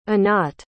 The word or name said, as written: Anat